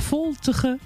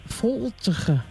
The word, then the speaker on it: voltige: We hebben nog voltige, draf of raar.